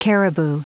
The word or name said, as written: caribou